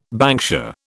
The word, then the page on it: banksia: British English